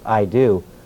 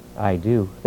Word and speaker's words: eido: That comes from the Greek word eido, E-I-D-O, which is pronounced eido, or it's actually pronounced I do, which is really interesting. I do.